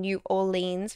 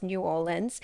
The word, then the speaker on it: New Orleans: So I was saying New Orleans for a while, but people have said, no, it's New Orleans.